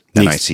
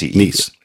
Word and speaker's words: Nice: Nice. – Nice.